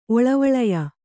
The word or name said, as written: ಒಳವಳಯ